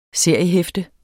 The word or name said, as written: SERIEHEFTE